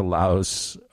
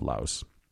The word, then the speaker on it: Lao: So mostly people that have been to Laos or have visited, you'll know because they might call it Lao instead of Laos. But the actual proper pronunciation inside Laos is Lao.